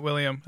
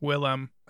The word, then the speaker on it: Willem: William Defoe. – Yeah. No, Willem. It's not William. It's Willem.